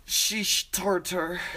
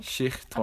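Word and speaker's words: Schichttorte: Sheesh tartar. – Schichtorte, I think. – Schichtorte. – I'm not German, so I don't know, but that's how they pronounce it.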